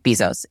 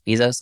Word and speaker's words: Bezos: And I was listening to a breakdown about 2 weeks ago of Jeff Bezos, and which I found out it's pronounced Bezos, not Bezos.